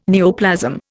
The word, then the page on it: neoplasm: Indian